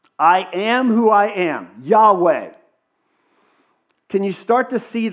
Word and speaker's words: Yahweh: But yes, as best we can guess, that is pronounced in English, Yahweh.